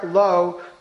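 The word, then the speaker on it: lo (לא): Now, it says, Asher lo krayim. It is written Lamed Aleph, but it's pronounced Lamed Vav.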